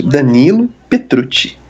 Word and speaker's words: Petrucci: Petrucci, olha, até errei a pronúncia. Danilo Petrucci.